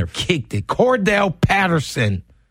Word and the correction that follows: Cordarrelle: Cordarrelle. – Cordell Patterson. – It's not even like Cordell.